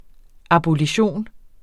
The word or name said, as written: abolition